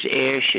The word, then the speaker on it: Oesch: Yeah, Stephen, with the P-H, S-T-E-P-H-E-N. Last name is Ish. It's spelled O. E-S-C-H.